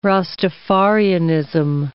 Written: Rastafarianism

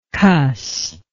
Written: cuss

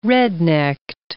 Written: rednecked

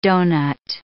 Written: donut

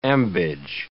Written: ambage

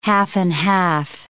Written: half-and-half

